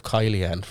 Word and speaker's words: Kylian: So when they're like, he said Killian instead of Kylian for Mbappe's name.